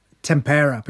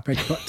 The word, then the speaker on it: tempera: I was experimenting with tempura. Am I saying that right? – Tempera. – Tempera. Tempura is the deep fried vegetables, isn't it? – Yep.